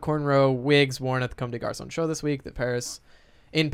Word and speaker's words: Comme des Garçons: Comme des Garçons.